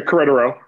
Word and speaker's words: Querétaro: Querétaro, did I say that right? – Yeah, Querétaro, yeah.